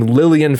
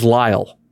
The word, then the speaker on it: Lyall: I went an entire series, over 100 episodes, pronouncing a guy's name Lillian Farrell. And his name is Lyall. like I didn't even change the nickname. It's L Y A L L. Lyall.